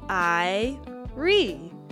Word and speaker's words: Irie: A friendly word you might hear is Irie. Say it like I-ree.